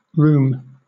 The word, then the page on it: rheum: British English